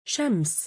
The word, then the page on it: shams: female